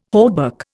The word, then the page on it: hauberk: Indian